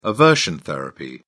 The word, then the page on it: aversion therapy: British English